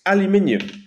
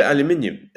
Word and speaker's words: aluminium: I'm just asking all of our American listeners, which is like 95% of everyone, please don't hate me, but it's aluminium. Just call it aluminium. It's how it's spelled.